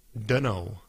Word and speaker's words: dunno: Bueno, dunno. I don't know. Dunno. ¿Han escuchado esto? I don't know, pero lo pronunciamos como dunno.